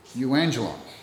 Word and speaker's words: euangelion: The word gospel in the Greek is a word that I'm not going to be able to pronounce quite right, but it's euangelion, which is where we get the word evangelism from.